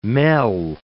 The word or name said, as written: mell